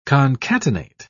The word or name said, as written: concatenate